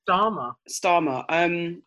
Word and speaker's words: Starmer: The new Labour leader, Keir Starmer, how do you say his surname? – Starmer.